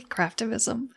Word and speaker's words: Craftivism: That's not, did I pronounce that correctly? Craftivism? – Yes.